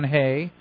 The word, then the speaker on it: Hoeh: John Hay, and the way you spell that last name, it's kind of unusual. It's H-O-E-H. – Oh. – Yeah, H-O-E-H. – Okay. – John Hay. Yeah, I know. It doesn't even remotely sound like it looks.